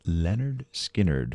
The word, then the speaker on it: Lynyrd Skynyrd: Going back to their debut, pronounced Lynyrd Skynyrd from 1973, with this one, Poison Whiskey.